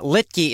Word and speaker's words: Littky: Now, Littke is spelled L-I-T-T-K-Y. Dennis Littke.